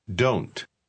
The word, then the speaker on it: don't: I don't understand. Ponga atención a la pronunciación. Note que el sonido final de don't casi desaparece y se junta a la palabra siguiente.